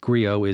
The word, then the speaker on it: Griot: Griot is G-R-I-O-T. The T is silent.